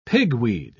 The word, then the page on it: pigweed: British English